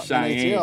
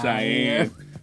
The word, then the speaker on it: Cheyenne: NHL I think it's pronounced Cheyenne. Look like Cheney to me. – It's Cheyenne. You had to remember how to say it. – Somebody from Wyoming called. Is it Cheyenne or is it Cheney? – It's Cheyenne.